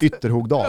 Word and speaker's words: Ytterhogdal: Ställs i premiären mot Härjedalens jävla stolthet Ytterhogdal. Jag vet inte ens om jag uttalar det rätt. Ytterhogdal. Ja, exakt.